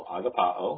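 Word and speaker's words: agapao (ἀγαπάω): Agapa and the long O, agapao.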